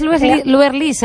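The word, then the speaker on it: Luerlís: Y me puso mi Luerlis y lleva un acento en la I, lo que pasa es que... – Ah, sí, es Luerlis entonces.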